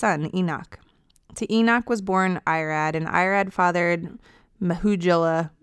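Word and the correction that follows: Mehujael: How do you say that? – I would say Mahujael.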